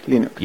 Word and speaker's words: Linux: Hello, this is Linus Torvalds, and I pronounce Linux as Linux.